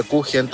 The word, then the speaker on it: Kuchen: La palabra Kuchen también es alemana. Y no se escribe con J, se escribe con CH, porque la CH en alemán es J.